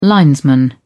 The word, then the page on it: linesman: British English